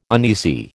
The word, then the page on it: uneasy: American English